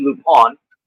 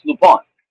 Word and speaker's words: Lupin: It's actually Lupin, but whatever. – Well, it's called Lupin. – Yeah, it is. It is 100% about Lupin, but in French, it's pronounced Lupin.